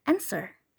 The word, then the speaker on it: answer: Yang ketiga, ada kata answer yang artinya jawaban. Bacanya itu bukan answer ataupun answer, tapi answer, answer, answer. Huruf V-nya tidak perlu dibaca.